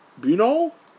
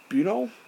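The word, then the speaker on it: Buñol: The Spanish town of Bunol? – Well, you're going to have to say that correctly. – Bunol? B-U-N-O-L.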